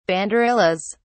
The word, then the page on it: banderillas: British English